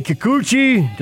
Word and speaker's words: Kikuchi: Seattle, you say Kikuchi.